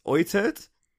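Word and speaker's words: Uitert: Okay, so for those playing along at home, it's that last name is spelt U... I think that's an I. U-I-T-E-R-T. And that's Oitert.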